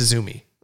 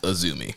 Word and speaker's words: Azumi: So yeah, her future reference, it's Azumi. – Right. Whether you see it spelled out or it's spelled AZM, it's pronounced Azumi.